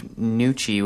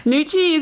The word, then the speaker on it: NUCI: It's N-U-C-I, a great charity. – When you said that, I was like, is it going to be like Gucci or not? – Not Gucci, no. – I know. NUCI is a nonprofit health and music resource center in Athens, Georgia.